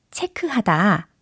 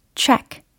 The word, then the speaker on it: check: 아하, 체크. 이렇게 아주, 어, 정확하게 발음을 해주고 계시는데요. 어, 그게 아니라, check, check. 이렇게 연습을 하셔야 되겠죠. C-H-E-C-K, check, check, check.